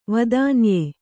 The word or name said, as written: ವೇದನೀಯ